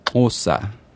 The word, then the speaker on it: Xhosa: I'm not going to pronounce it right, but the language that's spelled X-H-O-S-A is Osa.